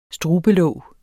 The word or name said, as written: strubelåg